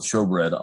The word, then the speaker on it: showbread: You're going to see the menorah on your left-hand side and the table of showbread on the right-hand side. I know it's spelled shoe, but it's apparently pronounced show.